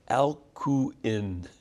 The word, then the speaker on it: Alcuin: Now, Alcuin is spelled A-L-C-U-I-N, Alcuin, but we say it Alcuin.